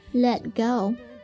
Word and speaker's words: Let go: 那发音的时候呢，我们要注意这个 let 词尾的 t 做吞音处理， Let go， Let go，表示放开松手，比如说 Don't let go the rope，抓紧绳子别松手， Don't let go the rope。